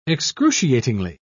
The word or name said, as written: excruciatingly